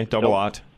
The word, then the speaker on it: double-aught: One of them wants a OO. I don't know how to say it. It looks like two zeros. – Okay, double-aught.